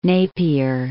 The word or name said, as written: Napier